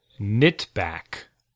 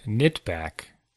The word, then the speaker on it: knitback: So it's the word is, this is all one word, knitback. – Knitback? – Knitback. Starting with a K, a silent K.